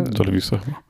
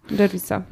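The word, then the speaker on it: Gervais: Nigdy nie wiem, jak się to wymawia. – Mhm, e, Gervisa. – Gervisa, okej.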